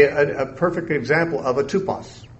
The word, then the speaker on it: tupos: The Greek word tupos, we would spell it T-U-P-O-S, tupos.